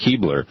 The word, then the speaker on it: Kuebler: His name is spelled K-U-E-B-L-E-R, but pronounced Keebler.